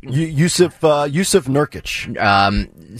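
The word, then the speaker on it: Nurkić: Yusuf Nurkic? – Yusuf Nurkic.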